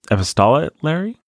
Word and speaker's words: epistolary: Scholars believe that Sense and Sensibility was first written in, I never know how to say this word, epistolary.